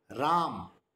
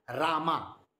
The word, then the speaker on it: Ram: Even the word Ram is not pronounced correctly. It is said Rama. Well, that is not the way how it is to be.